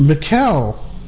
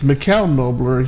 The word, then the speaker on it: Michele: I've actually been in a couple of listening sessions, and one of them is his name is actually pronounced Michele, and we said his name in the past was Michelle Nobler, but it's Michele Nobler. He's Irish, and of course we see the spelling in English as Michelle, M-I-C-H-E-L-E.